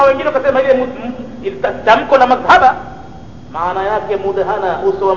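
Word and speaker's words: madhhaba (مذهبة): Neno madhhaba huwa bidhali almu'jama, linatamkwa kwa dhali yenye nukta juu yake. Fa fathil ha'i wa fathil ha'i, na kutiwa fatha ya he madhhaba, fatha ya he wal ba'i almuwahhada, na vile vile be inakuwa na fatha yenye nukta moja chini yake.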